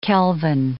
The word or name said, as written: kelvin